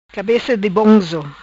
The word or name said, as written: Cabeca di bonzo